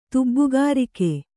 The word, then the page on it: ತುಬ್ಬುಗಾರಿಕೆ: kannada